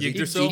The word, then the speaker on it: Yggdrasil: The only thing that movie sold me on was how to pronounce the world tree. When he says Yggdrasil.